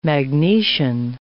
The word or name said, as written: magnesian